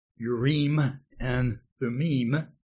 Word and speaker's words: Urim and Thummim: Close to the Hebrew pronunciation, our English pronouncing tongues want to say Urim and Thummim, but the actual Hebrew or closer to the Hebrew pronunciation is Urim and Thummim.